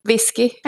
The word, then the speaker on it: viski: So how do you say whiskey in Finnish? – Whisky. – I think even I could manage that. – Yeah, it's basically pretty much the same. Viski.